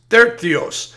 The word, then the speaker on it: Tertius: Okay, now in verse 22, it says, I, Tertius. And let's make sure we're saying his name right.